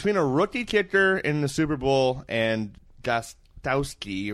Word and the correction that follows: Gostkowski: or whatever the Gostkowski. Use your words. – There it is. Yeah. – Just pronounce every letter in his name in order. You'll get it.